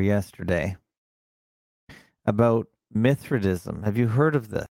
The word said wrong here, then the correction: Mithridatism: Let's see if I'm pronouncing that right. Mithridatism. – Mithridatism.